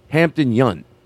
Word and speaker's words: Hampton Yount: It's Hampton Yount.